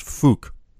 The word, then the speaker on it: Phuk: His first name was Fuck, but it's spelled P-H-U-K, which I later was told is pronounced Fook.